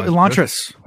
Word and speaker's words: Elantris: Am I saying it right? – Yeah, Elantra. Yeah. What about her? – No, I'm saying the wrong. What's the name? – Says Sanderson. – Sanderson. – Oh, Elantris.